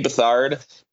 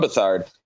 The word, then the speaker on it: Beathard: Beathard, B-E-A-T-H-A-R-D, which is not said how it's spelled.